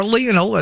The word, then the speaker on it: Lionel: Lionel Messi, or Lionel as it's sometimes pronounced, is retiring from the Argentina national team.